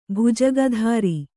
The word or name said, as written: ಭುಜಗಧಾರಿ